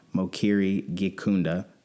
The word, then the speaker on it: Mukiri Gikunda: Thank you for tuning into the Dichotomies of Life podcast, The American Dream, part one, featuring Mukiri, Gikunda.